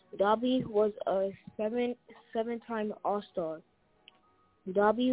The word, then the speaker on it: Doby: But his last name is pronounced Doby.